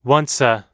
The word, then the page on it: once a: American English